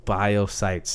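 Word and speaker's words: Biocyte: Yeah, it's just Biosite. C-Y-T-E. Biosite.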